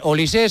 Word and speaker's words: Olise: Lleva acento, pero se pronuncia Olise. – Olise. O sea, es apellido francés. – Sí. – Vale, es que no sabíamos si lo de francés era por el padre o la madre. O sea, que es pero es raro que si no lleva acento que se pronuncie Olise. – Claro, ¿no? Si no lleva acento sería Olis. – Es Olis.